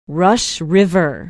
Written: Rush River

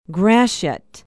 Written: gratiot

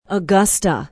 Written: augusta

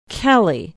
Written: kelly

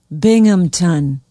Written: binghamton

